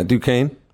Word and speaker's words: Duquesne: The Ohio Bobcats did lose to Duquesne. Is that how you say it? That really smart school? – Yeah, Duquesne.